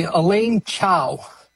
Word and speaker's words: Elaine Chao: Senator Mitch McConnell, his wife, I don't know how to pronounce her name, so please pronounce her name for me. Yep. – Let's see, Elaine Chao. – Is that's how you say the name? – Yeah.